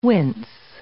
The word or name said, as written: wince